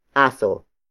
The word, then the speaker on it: aso: It is the focus marker, indicating that the next word is the focus of this sentence, and that is aso. Aso. Aso. Aso is spelled as A-S-O.